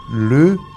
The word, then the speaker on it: les: Nosotros tenemos solamente le, L-E-S. No se pronuncia les, se pronuncia le.